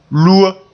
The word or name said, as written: lua